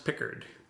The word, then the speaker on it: Picard: It's pronounced Pickard.